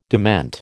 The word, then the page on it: dement: American English